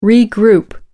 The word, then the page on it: regroup: British English